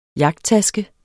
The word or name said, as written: jagttaske